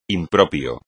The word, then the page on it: impropio: espagnol